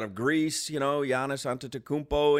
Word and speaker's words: Antetokounmpo: And here's how he pronounced Antetokounmpo. – Antetokounmpo for three. – Antetokounmpo for three. He nailed for three. He nailed that.